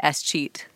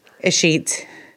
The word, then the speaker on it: escheat: First thing I needed to ask you is, how do you say escheat? – Escheat.